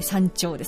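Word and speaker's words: 参朝: まずは秀吉、奉公三長列といいまして、豊臣公が、朝廷を三大する、だから、参る朝と書いて三長ですね。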